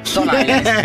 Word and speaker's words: Stone Island: Questi jeans della Stone Ainsen. – Di Jim, che c'è la questi jeans della Stone Islands. – Stone Island.